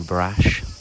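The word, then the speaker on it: Brač: I think the man at the car hire corrected my pronunciation from saying Brack to Brash.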